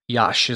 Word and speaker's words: Iași: So we got there first, and then the next morning flew immediately up to Iași is the name of the town. It's spelled I-A-S-I.